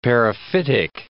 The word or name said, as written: periphytic